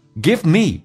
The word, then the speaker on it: give me: Give me 有一个缩读现象，变成 give me. V 不发音，长元音 me 的 E 说成短元音 E，所以变成 give me，叫做 give me.